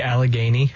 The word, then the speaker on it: Allegheny: The Allegheny.